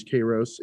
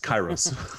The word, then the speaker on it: Kairos: Kairos in the configuration. – It's my job to mispronounce words. It's Kairos.